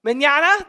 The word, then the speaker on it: mañana: Yeah, I do think as Christians we are called to live carpe mañana, how do you say it? Mañana, there we go.